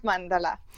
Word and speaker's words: Mandal: Mandal, ali kako se pravilno reče? – Ja, zelo pravilno ste izgovorili. Mandal. – Brez tistega umestnega prvega L-ja, oziroma tisti je tih ostane, ali kako? – Ja, se izgovori. Je pa res tiho, ja.